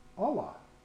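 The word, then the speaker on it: Allah (אלה): Aleph Lamed He, and it's pronounced Allah, is the word for curse.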